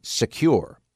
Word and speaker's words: Sekur: That's why I want you to try Secure. It's spelled S-E-K-U-R.